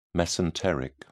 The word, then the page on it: mesenteric: British English